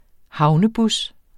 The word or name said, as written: havnebus